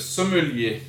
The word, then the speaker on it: sommelier: So, the sommelier, how do you say that? – Le sommelier.